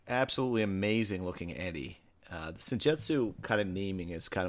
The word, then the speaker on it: Senjutsu: Senjutsu, is that how you say it? – Yeah.